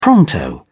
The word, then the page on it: pronto: British English